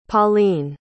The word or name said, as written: Pauline